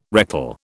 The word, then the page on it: rectal: British English